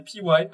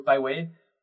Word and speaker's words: Piwai: P-I-W-A-I, prononcé P-Y, parce qu'à l'époque, je savais pas trop parler anglais quand j'ai choisi ce nom. parce qu'en anglais, ce serait plutôt Pyway, mais P-I-W-A-I, donc pour P-Y, puisque ce sont P-Y, ce sont mes initiales.